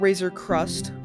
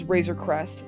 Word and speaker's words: Razor Crest: And it's not Razor Crust. My brothers always pronounce it Razor Crust. It's Razor Crest.